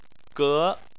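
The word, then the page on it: ge2: tone 2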